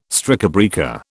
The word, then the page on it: strikebreaker: British English